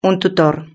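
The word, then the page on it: un tutor: Spanish (Spain)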